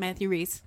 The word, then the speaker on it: Matthew Rhys: Matthew Rhys, right? Is that how you say it? – Matthew Reese.